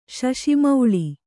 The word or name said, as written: ಶಶಿಮೌಳಿ